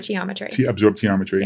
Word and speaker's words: absorptiometry: Absorptiometry.